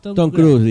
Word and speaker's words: Tom Cruise: Tom Cruise, dice.